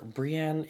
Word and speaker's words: Brienne: But we find ourselves in an inn where Brienne is, and please pronounce that properly, damn it.